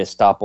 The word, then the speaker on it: estoppel: Now, also the legal principle of estoppel.